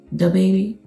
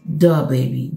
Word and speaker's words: DaBaby: It's not the baby or the baby. Is duh baby, duh baby.